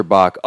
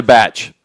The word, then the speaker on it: bach: It's a batch. – Sorry. It's spelled B-A-C-H, but like it's pronounced bachelor.